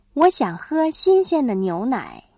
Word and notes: 牛奶: niúnǎi

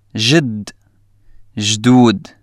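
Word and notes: جدّ (جدود): Syrian Arabic

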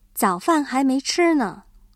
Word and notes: 早饭: zǎofàn

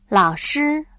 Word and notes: 老师: lǎoshī

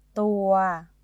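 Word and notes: ตัว: Thai

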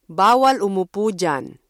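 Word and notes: diyan: Filipino

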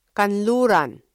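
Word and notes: kanluran: Filipino

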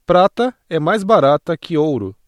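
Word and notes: barato: Brazilian Portuguese

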